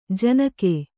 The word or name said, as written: ಜನಿಕ